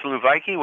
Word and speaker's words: souvlaki: Yeah, it's souvlaki, which is basically a shish kebab plate, okay, is what it is.